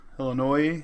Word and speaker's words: Illinois: Illinois. – The S is silent, you jackasses.